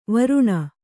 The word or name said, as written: ವರುಣ